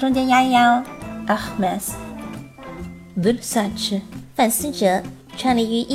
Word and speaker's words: Versace: Versace，很多的博主以及明星呢都会把这个 Versace 后面的 ch 读成了 ch 的音，但实际上根据这个范思哲家族的人来说呢，这个音应该是 Versace。 Versace。